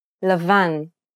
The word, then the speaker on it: Lavan: Let's take a look at some words that are stressed on the last syllable. Try repeating so that you can get a feel for the rhythm. – Davar. Kaved. Lavan.